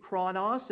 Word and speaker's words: chronos: That's how it be pronounced in the Greek, chronos.